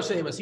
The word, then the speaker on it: Chavis: or what is that how you pronounce his name? Chavis? – Yeah, Michael Chavis.